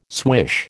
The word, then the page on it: swish: American English